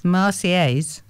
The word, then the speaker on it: Marseillaise: The Marseillaise. – Marseillaise. – Yeah. You don't say the L's are of silent. – No, it's a yes sound.